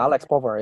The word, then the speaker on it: Povar: Like also, like, I think you still have a kid or one of the players from the Warsaw Mets, Alex Povar. How do you say his name? – Yeah, Alex Povar.